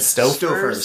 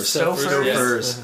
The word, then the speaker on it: Stouffer's: If you ever have tried lasagna, a nice Stouffer's with, did I say that right? – Stouffer's. – Stouffer's.